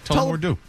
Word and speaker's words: Tullamore Dew: Have I pronounced that right? I should have looked at it. – Alcohol. I like it. – Yeah, you pronounced it correctly. Tullamore Dew.